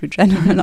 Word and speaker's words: Regeneron: Also diese Firma Regeneron oder also amerikanisch Regeneron ausgesprochen. – Ich habe es jetzt der Schreibweise halber, damit man es nachvollziehen kann, deutsch ausgesprochen.